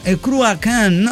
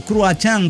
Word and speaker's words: Cruachan: Ellos son Cruachan, o Cruachan, como lo pronuncian, depende sí. – si usan el irlandés.